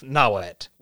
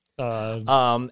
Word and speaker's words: Nahuatl: Nahuatl is spelled Nahuatl. – Okay. – Again, silent L.